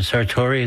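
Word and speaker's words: Sartore: is it Sartori? Is that how you pronounce it? – Mm-hmm.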